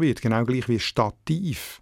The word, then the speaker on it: Stativ: Und wie man hört, hat Stativ noch die gleiche Betonung wie das lateinische Stativus.